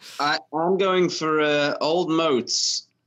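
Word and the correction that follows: Old Mout: Obviously, alcohol free. – Actually, it's pronounced Old Moot.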